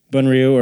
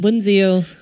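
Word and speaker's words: bún riêu: Bun Ryu or Bun, what's the northern pronunciation? – Bun Ziu.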